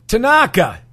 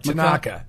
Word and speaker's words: Tanaka: Mikel Tatanka? – Tanaka. It's not hard. Tanaka. – And what's his first name? Makamu? – I don't even I'm just calling him Tanaka. You know, we had a Tanaka, but he was an infielder.